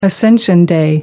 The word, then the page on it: ascension day: American English